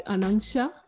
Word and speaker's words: Anangsha: Am I pronouncing it right, Anangsha? – It's correct, absolutely correct.